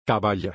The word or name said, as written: caballa